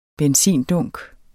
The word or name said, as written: benzindunk